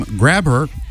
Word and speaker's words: Grabher: In the 1990s, a man in Dartmouth had a vanity plate made for his father with the family's last name, Grabher, which is spelled like it sounds.